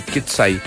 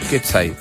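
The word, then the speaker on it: Coetzee: Así dicen que se dice Quetzai, se escribe Coetze, pero es Quetzai.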